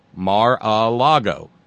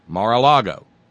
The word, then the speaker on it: Mar-a-Lago: And it's spelled M-A-R hyphen the letter A hyphen L-A-G-O, Mar-a-Lago, Mar-a-Lago, which I guess is sea by lake or, who gives a damn.